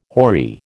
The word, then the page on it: hoary: American English